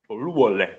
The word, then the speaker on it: Oluwole: Louvolé, que seria a pronúncia correta do nome.